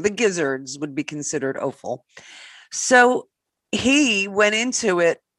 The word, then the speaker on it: offal: For those that don't know what offal is, it's O-F-F-A-L. – It's awful. – And he was actually pronouncing it incorrectly. He was saying awful, and it's not awful. It's offal.